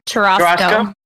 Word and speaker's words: churrasco: Churrasco? It's like a churro and a Costco. – Churrasco.